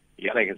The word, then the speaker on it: Janek: Janek is right.